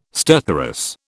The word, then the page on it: stertorous: British English